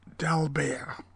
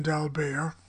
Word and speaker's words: d'Albert: And don't any of you out there say that the T should be pronounced. I knew, as a young person, several famous concert artists, much older than myself, who knew Eugen d'Albert. And none of them put a T on the end of the name.